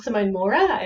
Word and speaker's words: Simone Mauer: Yes, it was Simone Maurer is how we would pronounce it, I guess, in Australia.